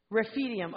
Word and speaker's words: Rephidim: It says, the Amalekites came and attacked the Israelites at Rephidim. That's a really hard word. That's it. Who said that? You. Say it again. Rephidim.